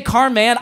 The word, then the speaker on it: Carman: By the way, who here pronounces it Carman versus does anyone say Carman? I know it's Carman. – I say Carman because his name was Carman, M-E-N, and he changed it to Carman.